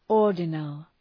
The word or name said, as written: ordinal